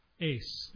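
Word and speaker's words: ace (εἰς): Mark uses this Greek word, and in English, EIS, ace, is really how you pronounce it in Greek.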